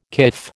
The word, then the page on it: kif: American English